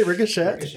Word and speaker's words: ricochet: You say ricochet? – It's just a fun little quirk about me. I like to pronounce the T's at the end sometimes.